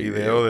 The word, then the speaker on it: IDEO: ¿Cómo se dice? – IDEO. – ¿IDEO? Ah, no jodas. Sí. IDEO, ¿no? IDEO. – IDEO de toda la vida. Estamos en español, hermano.